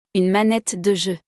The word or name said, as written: Une manette de jeu